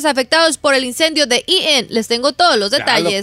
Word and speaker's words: Eaton: Well, that's how you say it, Eaton.